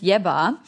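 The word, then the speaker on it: Yebba: Sie heißt Jebba und sie hat einen Song mit, ähm, also Mark Ronson produziert ihr gesamtes Album, ist sowieso ein fucking Genie.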